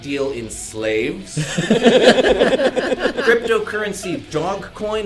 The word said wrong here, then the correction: Dogecoin: is actually pronounced with a soft J, as in Dogecoin, though it does have a dog-shaped mascot.